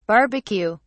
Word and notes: barbeque: British English

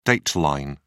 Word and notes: date line: British English

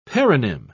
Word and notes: paronym: British English

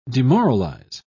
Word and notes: demoralise: British English

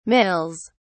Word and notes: mills: British English

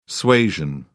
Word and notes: suasion: British English

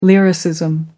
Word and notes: lyricism: American English

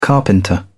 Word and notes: carpenter: British English

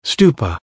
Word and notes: stupa: American English

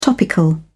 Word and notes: topical: British English

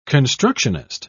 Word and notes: constructionist: British English

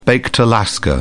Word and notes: baked alaska: British English

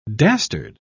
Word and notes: dastard: British English

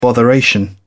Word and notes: botheration: British English

